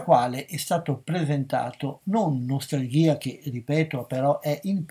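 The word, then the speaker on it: Nostalgia (Ностальгия): si insiste sull'utilizzazione della pronuncia russa, Nostalgia, che è un viaggio di un intellettuale attraverso l'Italia.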